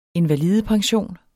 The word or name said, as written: invalidepension